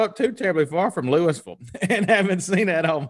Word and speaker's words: Louisville: So in Kentucky, it's Louisville. It's not Louisville. You know, they'll get on the announcers when they call it. It's Louisville, Louisville.